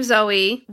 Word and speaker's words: Zoe: So the name Zoe, whether it's spelled Z-O-E or Z-O-E-Y, are both pronounced Zoe, not Zoe and Zoe. Both just Zoe.